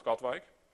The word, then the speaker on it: Katwijk: It's, and I'll pronounce it in Netherlands, it's called Katwijk.